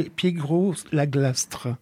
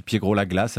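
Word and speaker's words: Piégros-la-Clastre: Ils se dérouleront du 11 au 15 septembre à Blacon et Piégros-la-Glastre, si je l'ai bien prononcé. – Ouais, je pense que ça va à peu près. On dit aussi Piégros-la-Glace, apparemment.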